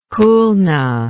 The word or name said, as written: Khulna